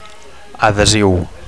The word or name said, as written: adhesiu